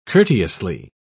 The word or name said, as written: courteously